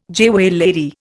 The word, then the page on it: jewellery: Indian